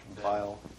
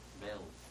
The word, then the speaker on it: Byl: B-Y-L. John Bill. Bile. Bill? – Bill.